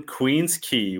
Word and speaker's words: Queen's Quay: For our listeners, if you're ever in Toronto, where you want to go is on Queen's Quay, which is spelt not the way you think it's spelled, which is the lakeshore.